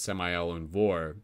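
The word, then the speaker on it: Samael Aun Weor: And just for for those of you who heard Samael Anvor, for those of you looking to type that in on Google, it's Samael, S-A-M-A-E-L, and then A-U-N-W-E-O-R. So it's pronounced Samael Anvor, but spelt with a W at the end.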